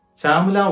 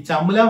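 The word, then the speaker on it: Chamula: ചാമുല Valley ഈ ചമുല Valley എന്ന് പല സ്ഥലങ്ങളിലും എഴുതി വെച്ചിട്ടുണ്ടെങ്കിലും ഇതിന്റെ ശരിയായ ഉച്ചാരണം ചാമുല വാലി എന്നാണെന്ന് ഒന്ന് രണ്ട് വെബ്സൈറ്റുകൾ സന്ദർശിച്ചപ്പോൾ എനിക്ക് മനസ്സിലായി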